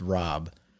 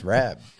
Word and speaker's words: Rabb: No, I was laughing because, like, when you're saying Rob, like, because the producer's name is Rob, and my last name is supposed to be pronounced Rob, but my whole life I grew up here in Philly, so then everyone just said Rabb, and I just was over it, and I was like, it's Rabb, you know, like, not going to correct every single person I encounter.